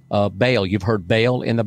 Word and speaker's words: Baal: And if you know the pronunciation of the real pronunciation, our English Southern, ever how we do it, we say Baal. Mm-hmm. The real pronunciation is Baal. That's how you say it. It's called Baal. B-A-A-L, Baal.